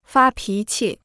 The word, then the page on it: 发脾气: fā pí qì